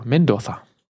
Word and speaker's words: Mendoza: Ein Brandy, ein spanischer, Cardinal Medoza. Medoza oder Mendoza? Mendoza.